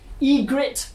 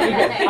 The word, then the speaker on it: Ygritte: Megan has come as, I'm going to say it wrong, Ygritte. – You did it! – I did it, Ygritte.